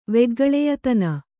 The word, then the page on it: ವೆಗ್ಗಳೆಯತನ: kannada